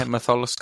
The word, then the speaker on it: Matholwch: Have I said that right? Matholwch? – Yeah, Matholwch Cauldron, that's it.